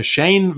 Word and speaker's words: Schoen: Joe Schoen, äh, Shane wird ein bisschen ausgesprochen, äh,